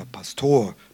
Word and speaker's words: Pastor: Im frommen Siegerland heißt er der Pastor, Betonung auf der zweiten Silbe, um zu zeigen, wir sind ja was Besonderes, wir sind keine Norddeutschen.